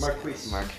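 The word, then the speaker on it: Marquez: Marcus Valdes-Scantling? Was that correct? – Marcus Valdes. – Marquise.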